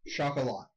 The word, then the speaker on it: Chocolat: The next movie, Chocolate. – No, Chocolat. – Chocolat. My bad. – It's a romance movie. – Yeah, not seen it. – I haven't either. I just know you pronounced it wrong.